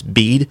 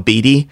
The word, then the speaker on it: Bede: It's by a, a, monk whose name is, I think it's properly pronounced. It's Bede. I always call him Beady.